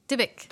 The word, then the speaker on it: Dybbuk: Dybbuk.